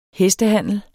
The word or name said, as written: hestehandler